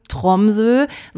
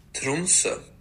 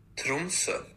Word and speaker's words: Tromsø: Ja, und ich sage ja hier die ganze Zeit immer so relativ plump, Tromsø. So würde das natürlich ein Mensch aus Norwegen auf gar keinen Fall aussprechen. Deswegen habe ich das mal für euch gegoogelt, wie man das richtig ausspricht. Moment. – Tromsø. – Ja, das klingt besser, ne? Wir hören es nochmal. – Tromsø. – Also, ich bleibe jetzt bei der Folge hier mal bei Tromsø. Das ist die deutsche Aussprache. Aber wenn ihr Profis sein wollt, dann Trumse.